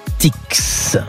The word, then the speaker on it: Artix: Il est en Pyrénées Atlantique, ce magasin U, et il est à Arte. Artix. On prononce bien le X de Artix, comme Astérix.